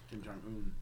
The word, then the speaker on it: Kim Jong-un: King Jong Un, right? – Kim Jong-un.